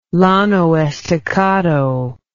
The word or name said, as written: Llano Estacado